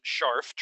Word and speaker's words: Scharf: You pronounce it Scharf, Charles Scharf.